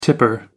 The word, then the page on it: tipper: American English